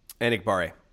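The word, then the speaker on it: Enagbare: So I think we'll see a lot of Garvin and Hamilton and Ramsey and the kid from South Carolina whose name I can never say. – Enikbare. – How do you say it? – J.J. Enikbare.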